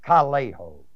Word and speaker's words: kaleo: The word call is the Greek word kaleo, K-A-L-E, long O, which means to turn to.